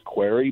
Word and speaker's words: Query: And I do so from a personal standpoint as well, because my last name is Query, but it's spelled Q-U-E-R-Y, which is a very slippery slope.